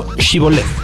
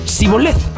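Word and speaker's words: shiboleth (שיבולת): Cada que se encontraban con un desconocido, le pedían decir la palabra en hebreo shiboleth. La palabra por sí misma no tiene ningún significado importante, pero las personas que no eran galaditas lo pronunciaban siboleth Así que con solo el sonido de la S.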